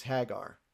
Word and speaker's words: Haggar: one thing that was said to me before was that it was spelled H-A-G-G-A-R. – Yeah. – And that should be pronounced Hagar instead of Hagar. – Like Yagar. – Yeah. So okay. I sometimes get caught saying Hagar and I don't really mean to, but it's Hagar.